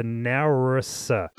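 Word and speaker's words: NAURSA: I'm going to have a stab at pronouncing this, and I know I'm probably not meant to, but it's the Naurissa. – Yeah. – That's how it's said, right? – Good job, mate. You nailed it.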